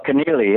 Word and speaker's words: Keneally: but it's Thomas Keenerly, the guy who wrote Schindler's List, right? – Oh, Keenerly, yeah, that's the one.